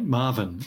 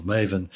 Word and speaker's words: MAVEN: We know quite a lot about Mars's upper atmosphere from spacecraft like Marvin or Maven, depending on how you want to pronounce it, which is uh, in orbit around Mars at present.